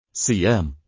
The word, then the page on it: siam: arabic